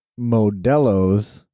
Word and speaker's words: Modelo: Well, I even know Shred, our buddy Shred, has been pounding nothing but Modelo's.